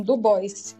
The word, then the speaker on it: Du Bois: E para relembrar também uma forte citação do William Du Bois, ele pronunciava assim, Du Bois, e não no francês,